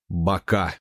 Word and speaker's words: Бака: 5 музыкантов, техник Андрей Сивиев по прозвищу Панк, светорежиссер Никита Краснов, звукорежиссер Алексей Бондаренко, он же Живодер, и турменеджер Андрей Меликов, известный под именем Бака. Ударение на последний слог.